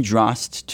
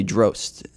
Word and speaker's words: Drost: And I mean Drost to sort of rhyme with frost and sort of spelled with frost with a D instead. But so many people say Drost.